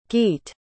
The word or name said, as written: geat